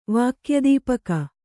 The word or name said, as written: ವಾಕ್ಯದೀಪಕ